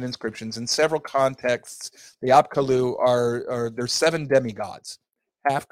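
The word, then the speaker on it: Apkallu: Yeah, and Heiser pronounces that Apkalu. – Apkalu, okay, yes.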